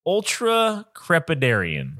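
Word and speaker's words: ultracrepidarian: Ultra crepidarian. Ultra crepidarian. – Ultra crepidarian.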